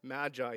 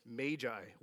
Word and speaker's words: Magi: It's apparently not Magi, it's Magi, which sounds really weird to me. But I checked, I did the pronunciation button.